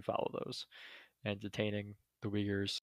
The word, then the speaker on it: Uyghurs: Mostly on the detainment of, I'm going to butcher how to pronounce this, but it's pronounced Uyghurs. – Uyghurs, okay.